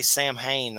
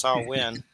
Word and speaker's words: Samhain: I always say Samhain. That's not how you say it. It's Samhain. Samhain.